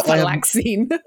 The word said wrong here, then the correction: Venlafaxine: Venlafaxine? – Yeah, I guess that.